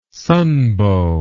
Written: sunbow

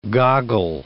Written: goggle

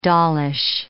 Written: dollish

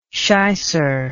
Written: shicer

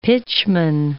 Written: pitchman

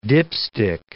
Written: dipstick

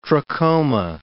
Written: trachoma

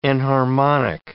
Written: enharmonic

